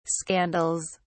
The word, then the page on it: scandals: British English